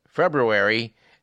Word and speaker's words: February: It's been a tradition on this program to point out every year that February has two R's and both are pronounced.